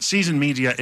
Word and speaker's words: SZN Media: But Season Media it's spelt SZN.